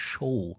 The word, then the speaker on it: Xow: die nennt sich Show. Das wird mit XOW, das ist wohl wahrscheinlich, weil dieses X wird da im Maltesischen wohl mit SCH ausgesprochen.